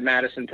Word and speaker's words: Matteson: I'm on Twitter at Matteson Perry. My name is spelled MatteSon.